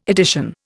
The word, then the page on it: edition: Indian